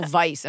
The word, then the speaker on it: Weisz: You have the wherewithal to say Rachel Weisz and pronounce the W like a V.